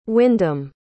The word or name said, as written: wyndam